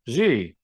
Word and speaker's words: Xi: Xi. Spelled with an XI. – Yeah. – XI pronounced Xi.